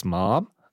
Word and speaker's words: Mam: this restaurant is spelled M-A-M, but there are funny accents on the A, and the accent in that case means that the intonation goes up. – Yep. – So it's Mom. – Yeah, Mom.